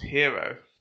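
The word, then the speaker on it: Gyro: It's pronounced hero. – Or year. – Yeah. – It's like hero.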